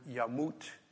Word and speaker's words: yamut: God is referenced twice, And the third one, the sad Hebrew word yamut, which rhymes with kaput, which means death.